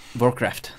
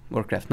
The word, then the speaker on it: Warcraft: Prej neumíš správně vyslovit Warcraft. Warcraft. Warcraft. – No, to je takový ten zvyk. Já jsem si, prostě, když jsme byli malí a hráli jsme Warcraft, tak jsme hráli prostě Warcraft.